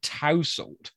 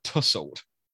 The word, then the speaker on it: tousle: But like it is spelt T-O-U-S-L-E, like tousle, how the Americans say it. So it's just interesting that we pronounce that tussle.